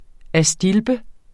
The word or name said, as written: astilbe